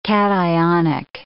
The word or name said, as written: cationic